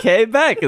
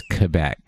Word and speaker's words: Quebec: Quebec, is that not how you say it? – No, it's Quebec.